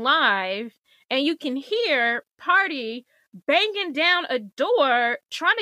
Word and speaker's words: Pardi: Megan Thee Stallion is dating some rapper guy named Party. Party, it's spelled P-A-R-D-I, Party Fontaine.